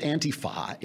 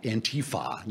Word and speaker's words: Antifa: this thing with Antifa, Antifa. Now we got to put the accent on the T.